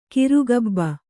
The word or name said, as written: ಕಿರುಗಬ್ಬ